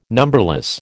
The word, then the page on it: numberless: American English